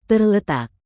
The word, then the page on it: terletak: indonesian